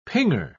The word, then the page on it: pinger: British English